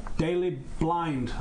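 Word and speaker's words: Daley Blind: Now, I saw a player for Ajax called Daily Blind, I think he's called.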